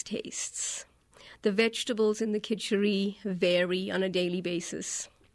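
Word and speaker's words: kitchari: I want you to explain what kitchery is. K-I-T. It's spelled different ways, but I think basically it's K-I-T-C-H-A-R-I.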